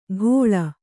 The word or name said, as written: ಘೋಳ